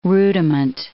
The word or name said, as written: rudiment